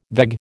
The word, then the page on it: veg: American English